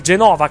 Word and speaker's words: Genova: Lei si chiama Poli Genova. È stata lanciata a livello internazionale, anzi Genova, che dir si voglia, la pronuncia corretta, è stata lanciata a livello internazionale proprio dall'Eurovision nel 2011, ha partecipato con Na Inat, non arrivò però in finale.